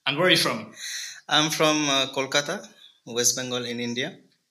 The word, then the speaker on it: Kolkata: Yeah, and it's recently has been changed from Calcutta to Kolkata officially in India. – Okay. – So that's why we like to prefer it as, like to pronounce it as Kolkata.